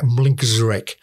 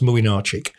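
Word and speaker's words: Młynarczyk: I mean, if you look at a name, right, Poland had a goalkeeper spelt M-L-Y-N-C-Z-A-R-E-K. Looks like Mlinczarek, pronounced Mlinarczyk.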